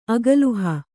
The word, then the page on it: ಅಗಲುಹ: Kannada